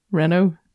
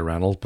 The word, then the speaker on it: Renault: How do you say it? – Renault. – I say Reynolds, but then I've said that for years.